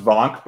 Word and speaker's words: Wank: It's definitely pronounced Vonk, but it is spelled Wank, which is great.